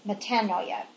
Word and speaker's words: metanoia: I used to say metanoia, but the proper pronunciation is metanoia.